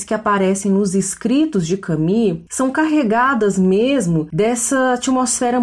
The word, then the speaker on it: Camus: Mas antes de falar um pouco desse romance, convém passar rapidamente pela pela autoria, pela figura obra de Albert Camus, que é o autor, é um autor franco, era um autor franco-argelino. É, o Albert Camus, apesar do nome dele se escrever Camus, se pronuncia Camus, justamente por essa origem francesa.